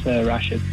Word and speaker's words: Rashid: Hey, it's Rashid, but okay.